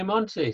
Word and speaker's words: Monty: People call me Monty, so you know that's cool.